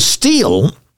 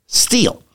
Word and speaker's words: Stihl: So Steel is this great company out of, I believe it's Germany, that makes top quality equipment, absolutely top quality equipment, S-T-I-H-L, but it's spelled, excuse me, it's pronounced Steel.